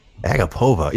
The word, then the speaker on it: Agapova: Maria Agapova. Is that how you say that? – Agapova? – Agapova. Yeah, I'm going with Agapova.